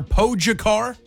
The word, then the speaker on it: Pogačar: The 5-time winner there, Pogačar, right, Jen?